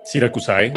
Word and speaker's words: Siracusae: pero la primera banda es Siracusae. así se pronuncia. Es latín, así que bueno, en italiano es Siracusa.